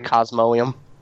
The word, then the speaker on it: Cosmoem: Is that how you say it? Cosmoem? – I think that is right. Cosmoem. – Cosmoem. It's not Eum, because Cosmoem. The E comes after the O.